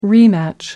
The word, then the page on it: rematch: American English